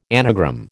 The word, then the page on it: anagram: American English